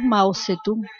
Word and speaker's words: Mao Zedong: El 9 de septiembre de 1976 muere Mao Zedong, que se pronuncia para nosotros Mao Zedong, el gran líder de la República Popular China, y su periodo de gobierno se caracteriza por la grandísima lucha ideológica.